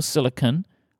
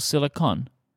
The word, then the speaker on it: Silicon: Do you pronounce it Apple Silicon or Apple Silicon? And did this change since Apple released the M1 Max? And people started pronouncing it like Apple Silicon, like fun at the end, rather than on at the end.